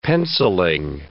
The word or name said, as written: pencilling